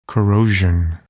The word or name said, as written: corrosion